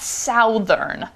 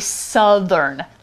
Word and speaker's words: southern: Also, note the pronunciation. It's not southern. A lot of people I know here say southern. – Mm. – So that should be southern, like cut, that uh vowel.